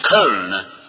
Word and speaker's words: Köln: It's Köln. K-O-umlaut-L-N, Köln. That's what it says outside the city. It's the way they all pronounce it.